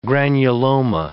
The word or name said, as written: granuloma